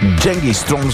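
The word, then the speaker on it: Genghis Tron: Genghis Strong всъщност е истинското им произнасяне, произношение.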